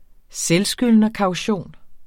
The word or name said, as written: selvskyldnerkaution